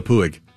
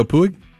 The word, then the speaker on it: Puig: dropped Yasiel Puig.